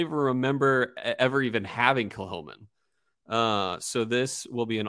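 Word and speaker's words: Kilchoman: And so we are drinking Kilholman Senig, which is, you might see it and read it as Kilcoman, but it's pronounced Kilholman. And it's spelled S-A-N-A-I-G is the release.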